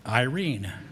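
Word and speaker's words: Irene (εἰρήνη): And that word, peace, in Greek, is Irene. Can you read it up there? It's spelled E-I. R-E-N-E. Now, I was going to try and pronounce that with all kinds of inflection and Greek stuff, but it's pronounced Irene.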